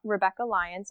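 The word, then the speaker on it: Rebekah Lyons: It's Rebecca Lyons. So it's R-E-B-E-K-A-H. L-Y-O-N-S.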